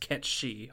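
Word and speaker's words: cat sìth: Well, in Irish folklore, there's something called the Catsey. Spelled Cat Sith. – Mm-hmm. I've heard it pronounced Ketchy also.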